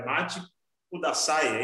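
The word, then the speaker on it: Matte Kudasai: É, desculpa se eu falar a pronúncia errada, é Mate Kudasai, é isso? Isso.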